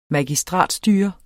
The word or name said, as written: magistratsstyre